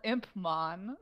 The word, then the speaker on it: Impmon: It's an English word. It's called an imp. Have you heard of that? It's called Impmon.